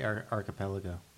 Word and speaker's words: archipelago: I can never pronounce this word. I can't pronounce most words, but archipelago, is that right? – I would say archipelago. – There you go.